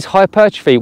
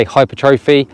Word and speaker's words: hypertrophy: Well, first of all, you'll hear it pronounced in different ways. People often say hypertrophy, hypertrophy. I say hypertrophy. I think one's probably American, one's probably English. I wouldn't worry about it. Say it however you like.